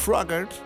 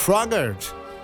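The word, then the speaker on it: Froggatt: Voor de input geef ik de Red Balloon van Raymond Fougard. Raymond Froggert, zo mag ik hem op zijn Amerikaans uitspreken. Raymond Froggert.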